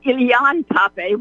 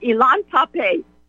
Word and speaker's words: Ilan Pappé: Never mind, he's pronounced it Ilan Pappé, which it is not, but Ilan Pappé was thrown out of Israel,